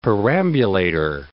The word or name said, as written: perambulator